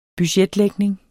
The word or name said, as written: BUDGETTER